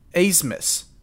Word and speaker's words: Abmas: We're playing Oral Roberts, and their best player, his last name is like A-B-M-A-S. He pronounces it Azmus.